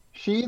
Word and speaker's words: Sidhe: Um so that that is Sid, uh S I D H E, right? Um the the like as in the Celtic fairies. – Um, yes, but it's, but it's pronounced, she.